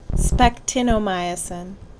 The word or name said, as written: Spectinomycin